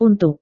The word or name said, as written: untuk